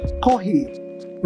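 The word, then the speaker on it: kohi (コーヒー): They pronounce coffee as kohi, with an H.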